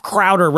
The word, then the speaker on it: Crowder: And so when you're reading a post from Seth Godin or Gary Vaynerchuk or Corbett Barr or Steph Crowder, rhymes with chowder,